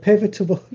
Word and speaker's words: pivotal: I was pivotable. I can't say this word. Pivotal. – You're adding an extra syllable in there.